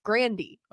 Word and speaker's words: Grande: But like, so I don't know if people know this, but Ariana Grande, the singer, her last name is actually pronounced Grandy. – Okay. – Like her whole family pronounces their last name Grandy, but like everyone just started saying Grande.